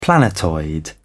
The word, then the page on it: planetoid: British English